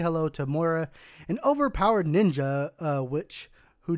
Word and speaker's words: Moira: New Overwatch hero reveals introducing, I'm probably going to say it wrong, but Moira? – Moira, yes.